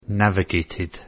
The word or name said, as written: navigated